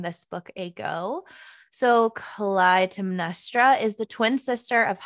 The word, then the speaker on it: Clytemnestra: It's, watch me struggle, it's Clytemnestra by Costanza Cazzotti.